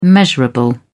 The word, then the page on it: measurable: British English